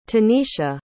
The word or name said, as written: tanisha